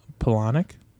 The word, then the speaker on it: Palahniuk: Palahniuk.